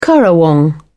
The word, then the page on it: currawong: British English